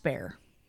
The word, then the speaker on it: Bexar: He then moved his troops to the Rio Grande, and the Siege of Bexar came to a close, which I had to look up how to pronounce that because it's Bexar. So if you come across that word at all, it's literally just pronounced bear.